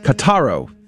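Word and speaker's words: Kotor: And when she was 12 years old, the visions were followed by an odd desire to travel to the town of Katarao. Uh, how do I say that? Kataro. Thank you.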